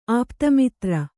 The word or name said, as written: ಆಪ್ತಮಿತ್ರ